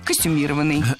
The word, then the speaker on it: костюмированный: Несколько раз прозвучало прилагательное «костюмированный» с ударением на третьем слоге. Однако такое ударение в слове было не всегда. Долгое время оно считалось неправильным, а литературной нормой был вариант с ударением на четвертом слоге «костюмированный». Но так никто не говорил, и филологи прислушались к гласу народа, норму изменили, и теперь правильное произношение «костюмированный».